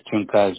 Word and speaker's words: Juncal: Do you pronounce yes. – the J? – No, you say Juncal.